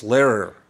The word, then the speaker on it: Lehrer: It's spelled L-E-H-R-E-R. I think it's Lehrer.